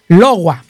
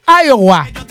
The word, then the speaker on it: Iowa: Bueno, que dice que no se pronuncia Logua. Dice que se pronuncia Haiwa, Haiwa. Dice como el chinito. Haiwa. No, dice que se pronuncia Iowa.